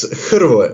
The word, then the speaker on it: Hrvoje: It's Hrvoje, Madiraca, but on this matter, Harvey is cool.